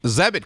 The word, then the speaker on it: Zebit: Zebit coming back for a third time, I believe. So I appreciate the support. Uh, Zebit that rhymes with debit